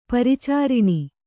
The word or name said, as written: ಪರಿಚಾರಿಣಿ